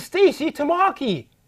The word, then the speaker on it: Stacie Tamaki: My guest tonight is Stacy Tamaki.